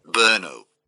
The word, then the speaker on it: Brno: Brno. – All right. This is how it's pronounced. – Brno.